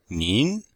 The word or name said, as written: nin2